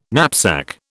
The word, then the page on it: knapsack: British English